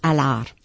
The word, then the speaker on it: Allard: The French way is Allard. The D is silent.